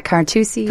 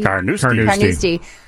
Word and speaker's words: Carnoustie: It's not Carnoustie. – Carnoustie.